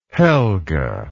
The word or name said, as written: helga